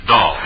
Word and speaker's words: Dahl: And incidentally, friends, Arlene Dahl is pronounced Dahl.